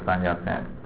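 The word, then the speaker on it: Sun Yat-sen: 好，我们先看啊，第一个名词片语啊，The first president of China was Dr. 三亚先啊，这个三亚先孙逸仙，孙中山的英文名字就是用孙逸仙啊，用广东话发音啊。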